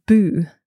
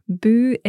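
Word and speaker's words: bùth: B-U-T-H with an accent on the U, so nice long oo, boo. – So that's a silent T-H at the end. – It is, yeah.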